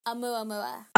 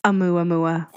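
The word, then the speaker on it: Amuamua (ʻOumuamua): Amuamua. – Amuamua.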